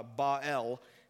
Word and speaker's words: Baal: Baal was an interesting god, and, and it's actually pronounced uh, Baal, if it's kind of like Ja. Uh, uh, what's Superman's dad's name? Eh? Yeah, it's kind of Jor-El, Baal.